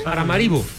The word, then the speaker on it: Paramaribo: Paramaribo.